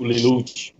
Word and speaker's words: Lelouch: O Lelouch.